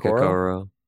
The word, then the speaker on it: Okoro: Isaac Okoro.